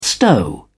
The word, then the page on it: stow: British English